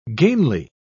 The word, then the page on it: gainly: British English